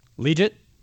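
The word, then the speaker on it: Liuget: They've got Kendall Reyes at defensive end, Sean Lismore, and Corey, how do you say it? – Legit.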